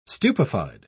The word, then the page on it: stupefied: British English